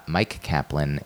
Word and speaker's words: Myq Kaplan: Uh, Myq Kaplan is spelled M Y Q Kaplan, K A P L A N.